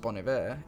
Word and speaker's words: Bon Iver: I think it's pronounced Bon Iver in French, so Bon Iver it is in English.